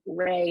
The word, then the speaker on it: Rae: So I'm Tina Ray Van on all social media. Ray is spelled R-A-E.